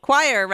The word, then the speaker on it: coir: I had to go to 3 garden centers before I could find potting soil using, and now I don't know how to say this, coir? Rather than peat moss? – Choir rather than peat moss.